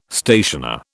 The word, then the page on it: stationer: British English